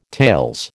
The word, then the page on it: tales: American English